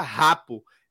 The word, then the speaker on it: Gakpo: É, Anderson tá lembrando que, que Gakpo é, pronuncia rapo.